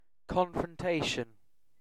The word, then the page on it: confrontation: British English